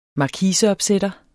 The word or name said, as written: markise